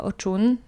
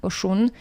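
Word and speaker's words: Oshun: Natomiast na przykład młode matki albo kobiety, które chcą zajść w ciążę, a nie mogą, zwracają się do oczun, oszun, też w zależności od regionu inaczej się wymawia,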